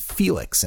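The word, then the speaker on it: Fiilex: Not every brand, not everyone out there, but one brand in particular is amazing, and it's by a company called Felix, and Felix is spelt somewhat odd. It's F-I-I-L-E-X.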